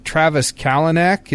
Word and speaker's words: Travis Kalanick: But Travis Kalanick, is that how you say his name? – Yeah.